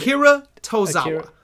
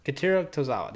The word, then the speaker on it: Akira Tozawa: You said Akira Tozawa. – Akira Tozawa.